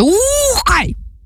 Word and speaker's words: Ухай: Ми говоримо про монгольський гурт, який називається «Ухай!». Отак він називається, тому що з наголосом, от, власне, на перший склад треба, і довгим звуком «У», і коли вимовляєш, це старайся робити трохи так з живота.